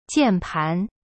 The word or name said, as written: 键盘